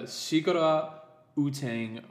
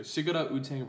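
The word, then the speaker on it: Sigarar Utang: It is a natural coffee, and it's Arabica from the Sigara Utang varietal. That's how you pronounce it. I wanted to make sure. Sigara Utang varietal.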